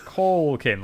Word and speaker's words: Culkin: Culkin, like a cauliflower. – Yeah. – Macaulay Culkin. – Macaulay Culkin. – And then they double down on it. The next paragraph is, in the film, Culkin plays Richard Tyler.